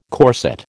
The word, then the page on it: corset: American English